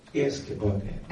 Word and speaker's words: Erzgebirge: in one region called Erzgebirge.